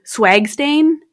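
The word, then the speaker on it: SWGSTAIN: And in the States, they have the Scientific Working Group on Blood Stain Pattern Analysis, which I kind of laugh at because it's condensed to swag stain. I mean, like, it's without the A, but I pronounce it as swag stain.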